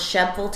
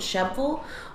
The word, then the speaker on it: Shelbyville: Shebville, Tennessee, which is spelled like Shelbyville, but we pronounce it Shebville, or McMinnville, which is spelled McMinnville.